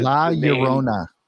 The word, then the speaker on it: La Llorona: They have a cigar, LL, I'm not going to be able to pronounce this. – La Llorona. – Thank you. That's it.